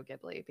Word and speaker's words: Ghibli: And so I say Studio Ghibli because it feels softer. – That's how I say it too. Because I believe it's spelled G-H, right? – Right. – Ghibli, right? So to me, if I see G-H together, I don't think J. – Right. I think of a softer G.